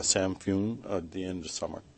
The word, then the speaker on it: Samhain: A lot of people call it Samhain, but the actual pronunciation is Samhain.